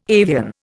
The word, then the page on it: avian: Indian